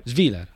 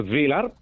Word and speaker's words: Svilar: Io domani sera vedo molto bene il portiere della Roma, che ha un nome impronunciabile per me, Svilar. – Ecco, Svilar, perfetto.